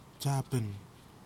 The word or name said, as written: tasiapen